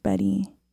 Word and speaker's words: Bettye: I think it's pronounced Betty. It's spelled Bettye.